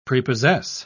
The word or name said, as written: prepossess